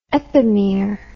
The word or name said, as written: epimere